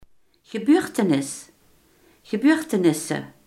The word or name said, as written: gebeurtenis